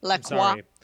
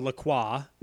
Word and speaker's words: LaCroix: Please pronounce it correctly. LaCroix. – A limoncello LaCroix.